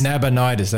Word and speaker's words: Nabonidus: How do you say it again? Nabonidus. Nabonidus, that's it. Thank you.